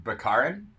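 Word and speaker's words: Baccarin: But it was Marina Bakarani. I can't say her name. Do you see that? – Marina Bakarin? – Yes.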